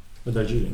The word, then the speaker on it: Darjeeling: Hoe dus spreek je Darjeeling ook weer? D-A-H-J of zo? D-A-R. J-E-E. Darjeeling, ja. E, e. oh, Darjeeling. Darjeeling.